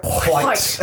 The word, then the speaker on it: white: White. – say white. – White. – Why do you keep saying it like that? – He's pronouncing the H. – Yeah, yeah. As you should.